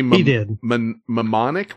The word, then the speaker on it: mnemonic: Johnny Mnemonic? What is it again? – Mnemonic. – I don't fucking mnemonic. – Know. – Mnemonic. – Mnemonic. – You don't pronounce the first.